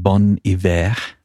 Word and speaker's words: Bon Iver: But so the band slash man is called Bon Iver. That's B-O-N space I-V-E-R. It's a French pronunciation.